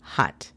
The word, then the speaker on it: hot: La pronunciación fonética sería J-A-P-I. Happy. Last and number three. La última y número tres. Hot. Hot significa caliente. La pronunciación fonética es J-A-T. Hot.